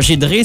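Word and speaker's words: Giedré: parce que ça s'écrit « Gidré », mais on prononce « Gidré », donc c'est « G-I-E-D-R-E » accent aigu. – G-I-E-D-R-E accent aigu.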